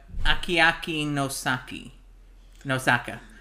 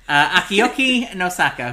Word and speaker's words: Akiyuki Nosaka: Let's talk a little bit about Akiyuki Nosaka. That is not how you pronounce his name. Akiyuki Nosaka, who wrote the novella Grave of the Fireflies, upon which this is based.